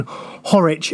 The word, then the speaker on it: Horwich: Thanks to Michael, whose Twitter handle, he has messaged us saying, for clarification, Horwich is pronounced the same way as Norwich.